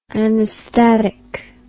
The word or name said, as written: anastatic